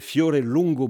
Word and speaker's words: Fiore Longo: Fiore Longo, bonjour. – Bonjour. – Voilà, Fiore, est-ce que j'ai prononcé correctement votre nom Oui, très bien, merci.